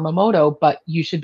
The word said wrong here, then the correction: Matomo: Am I saying that correctly? Momoto? – Matomo.